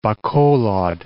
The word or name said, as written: Bacolod